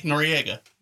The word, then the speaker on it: Noriega: This is spelled N-O-R-I-E-G-A. – Noriega. – Yeah, Noriega.